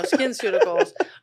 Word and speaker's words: SkinCeuticals: This whole time I was pronounced SkinCeuticals. – No, or I you could say SinCeuticals, the sin of fixing your skin. No, SkinCeuticals.